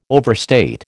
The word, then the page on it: overstate: American English